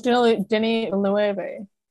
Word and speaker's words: Denis Villeneuve: No, it's Denis Villeneuve. Pronounce it right.